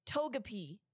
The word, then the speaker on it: Togepi: Wait, pronounce it again. – Tegepi. – Togepi. Like the Togepi. Pokemon.